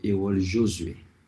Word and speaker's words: Errol Josué: Pero en Haití lo pronunciamos como Errol Josué. Uh-huh. Como en francés.